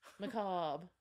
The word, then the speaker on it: macabre: Hank Harvey's macabre macro. They like that word, huh? Masterpiece. – Macabre. – Is that how you say it? Macabre. – Macabre.